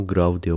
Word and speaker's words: Grave: Grave gibi yazılıyor ama Grav diye okunuyormuş. Filme başlamadan önce kısa bir açıklama yapmak istiyorum bu sefer.